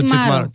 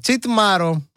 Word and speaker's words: τσιτμάρω: Και εμένα μου αρέσει πάρα πολύ το τσιτμάρω. Τσιτμάρω. No. – Έτσι το λέμε, έτσι τονίζετε. Τσιτμάρω. – Το λέμε καλά, τσιτμάρω.